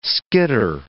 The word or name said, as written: skitter